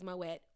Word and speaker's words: Moët: Oh, and while we're talking about Moët, it is Moët with the T. Period. The name is French, but the pronunciation is German.